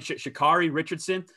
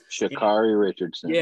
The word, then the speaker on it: Sha'Carri Richardson: Obviously, everybody knows, how do you say her first name again? Sha'Carri Richardson. – Sha'Carri Richardson.